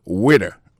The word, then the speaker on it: waiter: Alors, moi, je prononce le waiter. Waiter, ça veut dire accent tombé sous le premier syllabe là. Waiter, W-A-I-T-E-R.